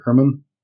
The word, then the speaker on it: Herrman: Tell people, who is Evan Herrman and why are you here today? Am I saying that right, Herrman? – Yep, you got it.